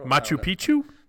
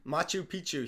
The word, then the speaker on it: Machu Picchu: Machu Picchu. – Machu Picchu.